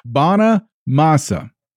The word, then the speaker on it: Bonamassa: You find Joe Banana Mana Masmo? – It's Joe Banana Masa? Bana Masa. B-O-N-A-M-A-S-S-A. – Okay.